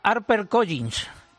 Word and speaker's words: HarperCollins: Bueno, se pronuncia bastante, yo lo diría HarperCollins.